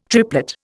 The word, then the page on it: triplet: Indian